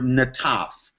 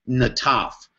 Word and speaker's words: nataph: and that's the word nataf. And you spell that N-A-T-A-P-H, nataf.